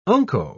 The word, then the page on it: unco: British English